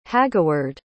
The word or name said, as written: hagaward